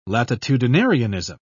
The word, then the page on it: latitudinarianism: British English